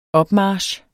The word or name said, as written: opmarch